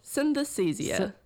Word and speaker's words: synesthesia: Well, so pronounce that word for me again, synesthesia. – Synesthesia. I was saying for ages, I wrote a whole article about it and I kept saying in the meeting synesthesia.